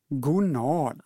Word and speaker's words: gonad: I avsnitt 50 gör Anders en ny amygdala när han säger gonad. – Mm. – Betoningen ska vara på A. Gonad.